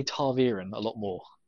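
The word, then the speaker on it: ta'veren: Now, yeah, the, it, the second syllable, excuse me, is, is veer, according once again to the glossary at the end of the actual physical book. Taverin.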